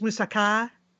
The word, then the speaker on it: moussaka: Uh, is that correct? It's Musaka. Eh, eh, yes, I mean I wouldn't overthink that, but yeah, that's what they would say in Greece.